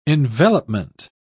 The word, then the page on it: envelopment: British English